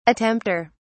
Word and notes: attempter: British English

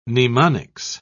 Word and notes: mnemonics: British English

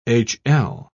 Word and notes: hl: British English

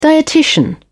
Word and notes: dietitian: British English

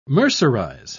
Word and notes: mercerize: British English

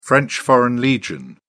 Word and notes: french foreign legion: British English

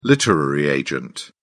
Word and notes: literary agent: British English

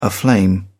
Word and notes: aflame: British English

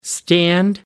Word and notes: stand up: American English

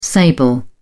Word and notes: sable: British English

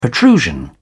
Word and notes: protrusion: British English

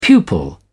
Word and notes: pupil: British English

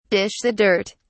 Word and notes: dish the dirt: British English

